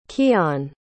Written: keion

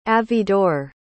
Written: avidor

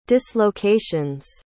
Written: dislocations

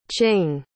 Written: qing

